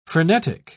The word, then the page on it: phrenetic: British English